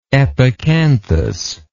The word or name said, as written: epicanthic